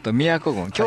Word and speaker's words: 京都郡: 宮古郡、京都と書いてね、宮古って読むんですね。